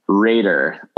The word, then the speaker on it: Raeder: Raider, although it's spelled R-A-E-D-E-R.